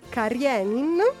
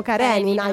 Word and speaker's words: Karenin: nelle varie opere si possono riscontrare tutti questi temi, ma una delle opere più famose è Anna Karenin, perché noi italiani ogni tanto la pronunciamo Karenina, invece sbagliato. Il nostro ospite ci ha insegnato che Karenin,